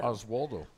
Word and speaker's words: Oswaldo: Speaking of someone who's been with a lot of teams, Oswaldo Arcia hits – am I saying that right? Oswaldo? – Yes. – Oswaldo. – Oswaldo.